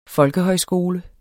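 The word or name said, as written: folkehøjskole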